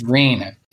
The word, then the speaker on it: renin: you got to say it correctly. So it's renin. And I think renin is like a uh, some enzyme or something in the cow's stomach. Yeah, it's, it's renin.